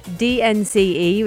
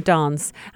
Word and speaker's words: DNCE: And so as it stands, DNCE, which is how I pronounce it, but you can call them Dance, and I'll tell you why it's called Dance in a minute.